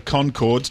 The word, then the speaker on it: Conchords: And before that, Flight of the Conchords. Don't forget the silent H in it. Chords as in music.